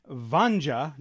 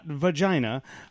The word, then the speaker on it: Vanja: Vanja, not vagina. Vanja. V-A-N-J-A.